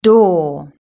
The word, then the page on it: door: British English